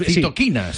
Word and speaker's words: citoquinas: Lo he dicho bien. Sí, citoquinas.